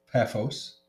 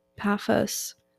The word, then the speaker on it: Paphos: Paphos. – Paphos.